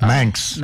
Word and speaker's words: Manx: Is it a minx cat? – Manx.